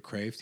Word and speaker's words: Kreeft: So the way to spell it is Kreeft. And he always makes the joke. It's Peter Kraft. He said, it's rock lobster, because Kraft means lobster in like Dutch or something.